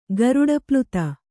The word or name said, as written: ಗರುಡಪ್ಲುತ